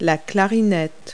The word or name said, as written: clarinet